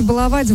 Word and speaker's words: баловать: Ударение в слове «баловать» в будущем изменится и будет падать только на первый слог, сообщил научный сотрудник Института русского языка имени Виноградова Владимир Пахомов.